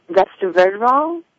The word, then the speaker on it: resveratrol: It's this thing called, I'm going to pronounce this incorrectly, and I apologize, resveratrol, I believe it is.